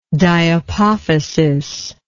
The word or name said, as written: diapophysis